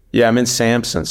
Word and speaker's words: Samsun: Samsun, that's how you pronounce it.